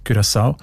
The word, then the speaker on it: Curaçao: Habe ich gelernt, spricht man eben mit «ü» aus, weil Holländisch dort auch eine wichtige Sprache ist. Und im Holländischen ist das «u» offenbar häufig als «ü», Curaçao.